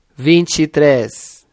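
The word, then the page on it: 23: Brazilian Portuguese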